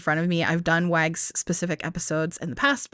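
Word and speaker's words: WAGGGS: It's the abbreviation, but said phonetically, WAGS. And it stands for the World Association of Girl Guides and Girl Scouts. So it's WAGS with three G's.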